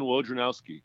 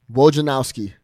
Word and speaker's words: Wojnarowski: Yeah, Adrian Wojnarowski. – Wojnarowski.